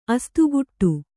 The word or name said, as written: ಅಸ್ತುಗುಟ್ಟು